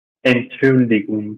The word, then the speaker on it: Entschuldigung: Entschuldigung.